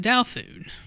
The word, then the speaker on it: taofu: You're probably thinking that's where tofu comes from, but taofu is pronounced daofu.